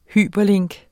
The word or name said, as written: hyperlink